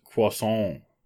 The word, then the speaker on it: croissant: Mine is croissant. – Croissants are pretty good. – Mm-hmm. There's no T in that word. Do not pronounce it. It is silent.